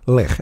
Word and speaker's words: Lech: Lech. L-E-C-H. – Lech. – You got to pronounce Lech.